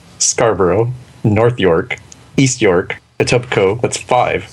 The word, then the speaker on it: Etobicoke: Pronounced Etobicoke.